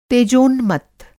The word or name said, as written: तेजोन्मत्त